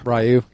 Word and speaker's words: Ryu: The original Street Fighter was when Ryu, or however you pronounce it, had red hair. – Ryu, yeah.